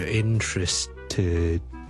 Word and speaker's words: interested: I'm very interested。听住 interested，要 t 翻出嚟咧，我先至一个完整嘅 ted，得唔得？I'm really interested。